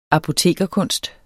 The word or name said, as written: apotekerkunst